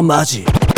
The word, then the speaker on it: 本気: 本気と書いて読み方はマジ。